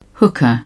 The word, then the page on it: hookah: British English